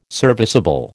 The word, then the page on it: serviceable: American English